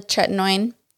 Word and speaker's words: tretinoin: And then tretinoin. Is that how you say it? Yeah, the tretinoin.